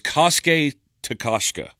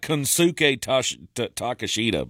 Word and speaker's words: Kosuke Takeshita: Konsuke Takeshita. No, I think he's not from here. – That's it. It was Kosuke Takeshita. It looks like Konsuke Takeshita, but that's not how you say his name.